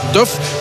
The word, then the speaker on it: Duff: En we krijgen dan Porosso vooraan, Fofana en ook een debuut van Duff. Zo moeten we het blijkbaar uitspreken. Mahmed Duff, zoals de Duffkes van Welles vroeger, van Welles en Wee.